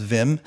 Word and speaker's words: Wim: So Wim Hof, actually, it's probably pronounced Vim.